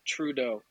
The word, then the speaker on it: Trudeau: Well, it's Trudeau. but that, yeah, just a pronunciation thing.